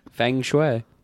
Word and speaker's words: feng shui: Feng shui. – What the fuck are you saying, you No, feng, not fuck. – Feng shui. – Feng. – F-E-N-G.